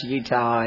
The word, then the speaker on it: Jutta: I'm not from here, and my name is strange, I know that, especially if you look at the program, it says J-U-T-T-A, and you wonder what a Jutta is. Well, it is it is pronounced Utah, and it's German, and the J is silent, and